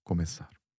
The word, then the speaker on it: começar: So, começar, né? Começar, C sigilia, A, R. So that's the final syllable, começar.